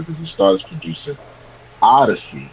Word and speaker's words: Odyssee: Any listeners out there, it's spelled Odyssee. So Odyssey.